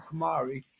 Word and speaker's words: KonMari: And definitely correct me on the mispronunciation of KonMari.